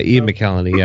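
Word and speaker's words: McElhinney: I am then going to do, and I can't pronounce his name, is it Ian McKellany, who's General Dodonna in Rogue One? – Yeah, Ian McKellany, yeah.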